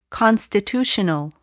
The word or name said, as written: constitutional